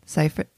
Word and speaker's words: Seyfried: Seyfried? How do you say her? – Seyfried. Yep.